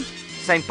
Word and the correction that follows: Usain: Usain Bolt.